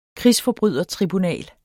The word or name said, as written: krigsforbrydertribunal